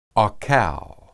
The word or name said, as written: akal